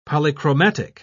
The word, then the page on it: polychromatic: British English